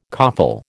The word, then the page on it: coffle: American English